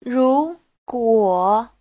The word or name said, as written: ru2guo3